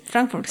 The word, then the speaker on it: Frankfurt: Frankfurt. No sé si se dice así. – Frankfurt, sí.